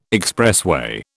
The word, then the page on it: expressway: British English